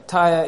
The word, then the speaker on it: Tyre: How do you pronounce this name? Just help me. Tyre. I could have said it differently. Okay, Tyre is the name we are going to, is how we are going to pronounce it. All right, the King of Tyre. Now, in my language, I could have called it Tyre, but Tyre is good.